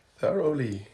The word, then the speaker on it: thoroughly: Also, wie würdet ihr das Wort hier aussprechen? Das oberste für die zweite Nachricht. – Thoroughly. – Thoroughly. Thoroughly. – Thoroughly. Thoroughly. – Weiß nicht. – Thoroughly, ja, stimmt.